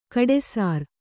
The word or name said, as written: ಕಡುಸಾರೆ